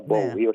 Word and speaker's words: Bowie: Θα ακούσουμε David Bowie, να το πω έτσι αγγλιστή στην προφορά, Bowie όχι Bowie.